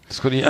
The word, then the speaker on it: Scott Ian: Scott, Ian, äh, Scott, ja.